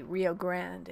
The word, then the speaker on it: Rio Grande: I guess we say Rio Grande in Texas. Sorry. I know you don't do the Spanish pronunciations when you're in Texas. I learned that from my parents who lived in Texas.